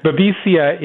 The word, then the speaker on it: babesia: No, don't ever call it baby. I did that once at a conference. Oh, they got so upset. No, babesia is actually a parasite.